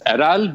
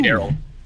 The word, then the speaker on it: Erel: Well, the Turkish pronunciation is Eral, but you guys can go ahead and say Errol.